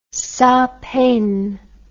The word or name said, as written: sans peine